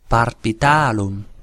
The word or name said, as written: barbitalum